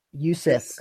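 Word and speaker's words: Jusuf: Justif Nurkic. – Yusuf, come on, get it right. – No, it's Justif. – The J is silent, my friend. – It's Yusuf.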